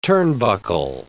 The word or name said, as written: turnbuckle